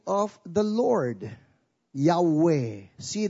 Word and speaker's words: Yahweh: When you see the Lord as all caps, the original Hebrew for that would be YHWH, which many Bible scholars would pronounce as Yahweh.